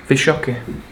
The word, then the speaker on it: Wysocki: Wysocki. – Yeah, that's the, right. Because the Polish pronounce the W's as V's.